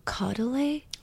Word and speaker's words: Caudalie: But I also use the Caudalie? – Yes. – That's how you pronounce it.